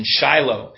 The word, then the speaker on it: Shiloh: Shiloh's the Hebrew as it's pronounced with the Masoretic text pronunciation, Shiloh.